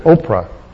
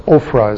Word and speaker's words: Ophrah: comes and sits under the oak at Oprah. This is, or Ophrah is how it's pronounced.